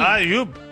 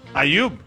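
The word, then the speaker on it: Ayyoub: Del día, usted que es el de los idiomas, dígame, porque he escuchado diferentes maneras en que se pronuncia Ayub. O Ayub, el 6 de Marruecos, Baudí. – Ayub. – ¿Cómo? – Ayub. – Ayub Boadí. – Ayub. – Baudí. Ayub Boadí.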